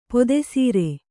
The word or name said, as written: ಪೀತಸಾರ